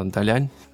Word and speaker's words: Далянь: буду говорить правильно, э, Далянь.